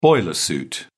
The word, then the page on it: boiler suit: British English